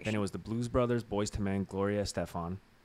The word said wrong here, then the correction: Gloria Estefan: Why did I say it like that? Gloria Estefan.